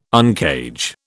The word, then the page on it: uncage: British English